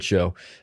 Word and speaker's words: shew: Or really, you pronounce that word show, even though it doesn't look like it.